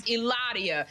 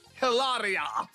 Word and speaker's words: Hilaria: Because I remember when I met her, like, she corrected me on how to say her name. It took me so long to pronounce Hilaria.